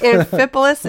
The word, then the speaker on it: Amphipolis: Amphipolis. – Amphipolis and Apollonia? – Yep.